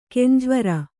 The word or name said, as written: ಕಂಜವೈರಿ